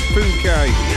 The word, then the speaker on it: Phuket: Let's not read it out the way it's spelt. We've not long started, have we? So, I think it's Phuket.